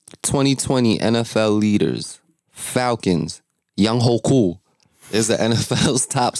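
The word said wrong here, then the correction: Younghoe: No, it's not. It's actually Young Way. – That's not what it is. Young Way. – Young Way Koo.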